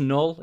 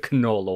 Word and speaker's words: Knull: It's either Knull or Null. I think it's Null. You never know with Marvel, right? They've got absolute batshit names. Null rules over the Abyss and is the creator of the symbiotes.